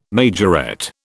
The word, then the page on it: majorette: British English